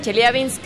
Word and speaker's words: Chelyabinsk: chichulú y Y al grito de se dice Chelyabinsk, ya nos, vamos.